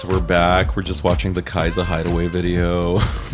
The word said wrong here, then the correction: Kesha: You called her Kaiza? – Isn't that how you say it? – Keisha.